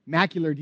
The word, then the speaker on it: macular: Molecular degeneration? Is that right? Macular degeneration. Thank you.